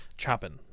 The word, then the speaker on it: Chopin: Chopin, I believe his name is pronounced.